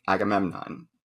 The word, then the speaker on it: Agamemnon: And he's doing these private lessons for Taplow on Agamemnon, which I'm not even sure if I said that correctly. – You did. Well done.